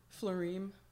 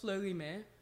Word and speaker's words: Fleurime: I actually always tell people the wrong pronunciation because it's easier. It is pronounced Fleurime.